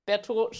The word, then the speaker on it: Bertheau: Bertot schreibt man ein bisschen schwierig. B-E-R-T-H-E-A-U.